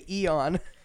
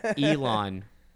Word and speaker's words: Elon: You were about to say Eon. – Elon. Elon, right? Don't die, please.